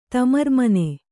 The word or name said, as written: ತಮರ್ಮನೆ